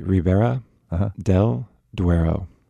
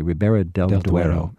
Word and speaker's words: Ribera del Duero: Can you pronounce that other wine in a way an American could understand? – So it'd be Ribera del Duero. – Okay, Ribera del Duero. – Exactly.